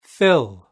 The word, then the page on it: fill: British English